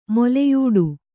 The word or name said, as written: ಮೂಲಿಯಿಡು